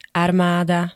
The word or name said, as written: armada